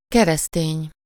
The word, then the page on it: chrétien: [kʁe.tjɛ̃]; France